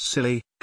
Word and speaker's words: Scilly: pronounced silly, Harold by his adversaries, since he often vacationed on the Scilly Islands, situated southwest of the British mainland, unexpectedly resigned in 1976, citing physical and mental exhaustion.